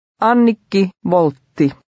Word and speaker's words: Annikki Voltti: Here the W is pronounced like the English V. Try to read number 3. – Annikki Voltti.